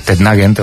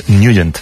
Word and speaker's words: Nugent: Hombre, siempre lo hemos llamado en el barrio Tednagent o Tednagent. – Claro, ¿no? Yo era de Tednagent. – Pero la pronunciación correcta es Nugent. – Te fuiste a Washington y descubriste que era Nugent.